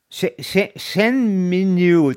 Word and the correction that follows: Shenmue: Du meinst Shenmue? Shenmue, genau.